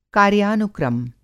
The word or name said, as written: कार्यानुक्रम